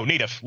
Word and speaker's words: Nedeff: No, Nedeff. Long E.